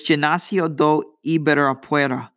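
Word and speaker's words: Ginásio do Ibirapuera: It was November 16th, which is this upcoming Saturday at the Gimnasio de Ibirapuera. Wait, I said that wrong. So it's Gimnasio do Ibirapuera.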